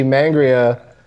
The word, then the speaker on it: Mangria: I don't want to interrupt you, but I see mangria. – We put an emphasis in the air. We pronounce it mangria. – Okay. – Kind of like sangria.